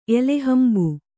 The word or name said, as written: ಎಲೆಹಂಬು